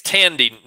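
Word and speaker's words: Thandiwe: This was Thandwe. Did I do that right? – I think it's Tandy Newton.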